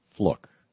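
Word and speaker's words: Fluke: Sandra Fluck had, and I'm sure I'm mispronouncing it. I understand it's F-L-U-K-E, and it's pronounced like book, Fluck.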